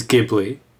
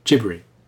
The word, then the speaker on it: Ghibli: The correct pronunciation of the word that it derives from is Ghibli, or Ghibri. The way it's rendered in Japanese, for whatever reason, is as Ghibri.